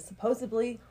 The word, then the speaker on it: supposedly: No, I'm saying you said supposedly wrong. Sup- Did I say that wrong? You said supposedly. It's with a D.